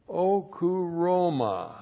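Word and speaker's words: okuroma (ὀχύρωμα): Looks like that in the Greek, O-C-H-U-R, long O-M-A, okuroma. Okuroma, you remember where we studied this?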